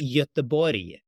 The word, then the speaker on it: Göteborg: In Swedish, it's Göteborg. In English, it's Gothenburg.